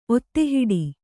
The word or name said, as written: ಒತ್ತೆಹಿಡಿ